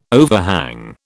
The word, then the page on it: overhang: British English